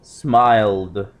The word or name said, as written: smiled